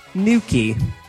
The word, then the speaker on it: Nukie: Nuki. – Oh, fuck. – It's spelled N-U-K-I-E. Nuki.